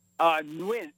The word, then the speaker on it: Nguyen: It's Nguyen. I just learned how to pronounce it myself.